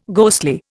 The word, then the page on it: ghostly: Indian